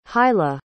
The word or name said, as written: hilah